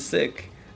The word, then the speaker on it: Sikh: How do you say the word Sikh? Because a lot of people say Sikh. – I used to say Sikh. I didn't know it was Sikh. – Yeah, it's that's sick. – Yeah, so it's the right way to say it is Sikh.